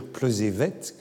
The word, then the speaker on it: Plozévet: Elle est née à Plozévé. Et elle me corrige même, elle me dit qu'il faut dire Plozévette, que le, le T final euh, se prononce.